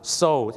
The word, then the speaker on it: sew: ซิวเหรอครับไม่ใช่ซิวครับผมซูโอไม่ใช่นะฮะไม่ใช่ครับซูเนี่ยนะครับภาษาอังกฤษเนี่ยนะครับจะเป็นชื่อผู้หญิงหรือจะแปลว่าการฟ้องร้องคดีก็ได้ครับคำนี้ออกเสียงว่าอะไรไหมครับโซถูกต้องแล้วครับ SEW ออกเสียงว่าโซครับ so